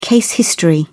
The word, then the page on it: case history: British English